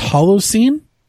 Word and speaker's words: Holocene: Is the last album, is it pronounced Holocene? – Yes, that's correct.